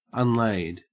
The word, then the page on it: unlaid: Australian English